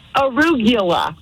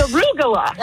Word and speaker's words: arugula: Arugula. – Is that how you say it? – No, it's arugula. – Right, call him right now. – It's arugula. – Arugula. It's like Caligula.